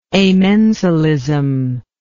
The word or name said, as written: amensalism